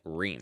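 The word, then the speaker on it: Reum: Room. – Ream. – Room. – Ream. – Okay. – Is it Room? – It's R-E-U-M, so I assume it's Room. – No, but I think they pronounce it Ream.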